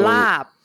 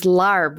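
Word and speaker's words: laab: But when Americans start using that same spelling, they pronounce the R, and it becomes larb, which is totally wrong. Okay. So I've been trying to preach that it's L-A-A-B, so that regardless of what accent you have, you are going to say it correctly.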